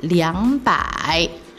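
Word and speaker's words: liangpai (两百): Erpai. Erpai. Tapi biasanya di Tiongkok mereka menggunakan liangpai, liangpai. Ya, liang ini artinya dua. Liang, liang, liang artinya dua. Nah, liangpai 200.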